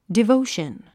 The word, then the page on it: devotion: divóuʃən; ディボゥション